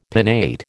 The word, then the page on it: pinnate: American English